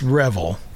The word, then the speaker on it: Revel: So that's Kershaw, who had a long friendship with the person you just heard, Gary Revel, or Revel. I believe it's Revel. – Yeah. – As far as how he pronounces it.